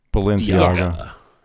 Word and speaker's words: Balenciaga: Balenciaga.